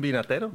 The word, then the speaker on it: vinatero: ¿Está bien dicho vinatero? ¿Cómo se lo dice? ¿Cómo se dice winemaker en español? – Pues sí, es un vinatero.